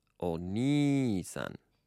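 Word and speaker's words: Oni-san (お兄さん): You'll actually notice that all of these words, the middle syllable has, or rather the second syllable, has a long vowel on all of these. So the word for older brother is Oni-san.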